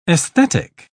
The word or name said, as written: aesthetic